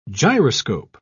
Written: gyroscope

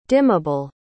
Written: dimmable

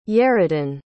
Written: yerodin

